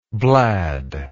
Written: blad

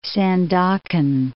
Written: Sandakan

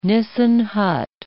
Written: Nissen hut

